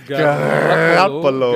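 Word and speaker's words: Garoppolo: Wow, Garapolo war so unter Druck. äh, Garapolo. Was hast du das gerade ausgesprochen? – Garappolo.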